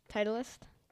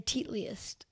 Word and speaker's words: Titleist: Titleist? – I didn't know you knew how to say it. I thought you were going to say Teatliest.